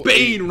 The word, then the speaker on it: Bane: I'm getting Mr. Bane, who broke the bat, who's also Bane rhymes with Lane.